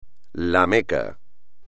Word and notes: La Meca: espagnol

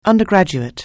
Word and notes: undergraduate: anglais

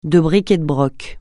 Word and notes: de bric et de broc: francais

